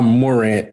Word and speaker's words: Morant: You keep calling him Ja Warren. I don't want the PPP, the Purple Pants Posse, to say his name. It's Ja Morant, but they are calling him Warrant.